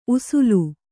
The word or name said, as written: ಉಸುಲು